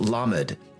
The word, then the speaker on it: Lamed: But it's actually pronounced Lamed. And the reason I'm saying it like that is because if you hear the differences between those two sounds, Lamed does not